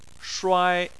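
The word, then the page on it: shuai1: tone 1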